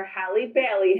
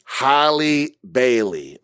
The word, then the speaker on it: Halle Bailey: 19-year-old R&B singer Halle Bailey has... – Halle Bailey, okay. Is going to be the... And you know that's how you pronounce it because a white lady said it.